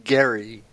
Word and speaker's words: Gerry: No, his name is Gerry. – And it's pronounced like Gary, like Jarry. – Very similar. – It's with a g and not a ga.